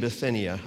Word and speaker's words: Bithynia: Peter, an apostle of Jesus Christ, to God's elect, strangers in the world, scattered throughout Pontus, Galatia, Cappadocia, Asia, and Bithynia. I looked it up. That's how you pronounce those names.